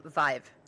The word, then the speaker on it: Vive: It's pronounced Vive. Because it rhymes with five.